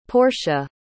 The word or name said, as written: Porsha